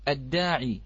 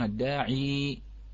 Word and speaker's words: الداع: مثال مثلا كلمة الداعي، كلمة الداعي، نعم نحن في حفص نقف عليها الداع، ولكن عند ورش أو عند البعض نعم يقفون الداعي، نعم بعض عندهم وجهين.